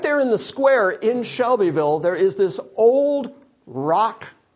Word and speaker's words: Shelbyville: And I don't know how familiar any of you are with Shelbyville, but right there in the square in Shelbyville, which, by the way, just the way I'm pronouncing Shelbyville proves that I'm not from Shelbyville. Yeah, it's Shevel. Shevel.